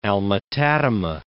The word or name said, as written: Alma-Tadema